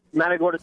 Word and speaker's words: Matagorda: Matagorda, Texas.